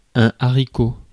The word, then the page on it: haricot: France; /a.ʁiˈko/